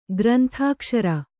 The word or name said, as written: ಗ್ರಂಥಾಕ್ಷರ